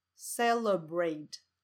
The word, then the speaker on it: celebrate: 所以第一个音节 a，你就把它念成呃呃，about，sofa，sofa，sofa， celebrate celebrate celebrate 这个字，它重音是在第一个音节 c 上面，然后完全不被强调的音节是 l e 这个音节，所以念成